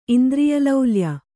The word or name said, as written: ಇಂದ್ರಿಯಲೌಲ್ಯ